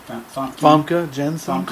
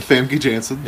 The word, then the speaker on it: Famke Janssen: Famke Janssen. – Cool, I just want to make everybody else do it before I did. – Famke Janssen, yep, the Dutch sensation.